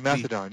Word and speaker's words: Mastodon: Would you go from Twitter to, is it Mastodon? Is that how you say it? – Mastodon.